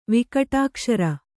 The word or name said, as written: ವಿಕಟಾಕ್ಷರ